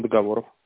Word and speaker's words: договоров: А почему договоров? – Потому что правильно говорить договоров.